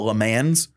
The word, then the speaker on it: Le Mans: It is technically Le Mans, but that's the French, like, purest pronunciation. A lot of Americans, well, the few Americans that actually do watch the race, and more should, they'll call it Le Mans.